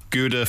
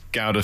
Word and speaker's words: Gouda: Gouda for the Yanks, Gouda for everyone else.